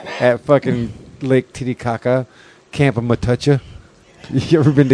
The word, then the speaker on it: Matoucha: It's spelled M-A-T-O-U-C-H-A. Matucha. – Matucha.